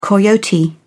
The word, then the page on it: coyote: British English